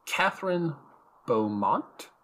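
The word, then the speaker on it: Kathryn Beaumont: But speaking of voices, so Alice's voice actor, let me get the name right, is Catherine Beaumont.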